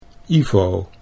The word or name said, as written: Ivo